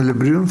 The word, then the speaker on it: Лебрюн: Начинается это все дело с 1718 года, с описания путешествия Лебрюна, или по-разному произносится в России, наверное, Лебрюн все-таки, да.